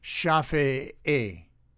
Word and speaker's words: Shafi'i: Capital S, H-A-F-I, and what do you call this? Apostrophe and another I. I think it's pronounced Shafi'i version of Islamic law, which is misogynistic, needless to say.